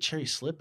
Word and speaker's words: Cherry Slip: Is it Cherry Slip or is it Cherry Lip? – It's like Cherry Slip Cherry Exotics. Slip.